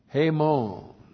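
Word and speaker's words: Hemon (ἡμῶν): And then we have ta kaukema hemon.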